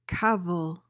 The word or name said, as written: cavil